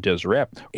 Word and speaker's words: Deseret: And in the lower kingdom, it talks about Deseret, and it's all consonants. There's no vowels in there. It's D-S-R-T, but it would have been pronounced something along the lines of Deseret.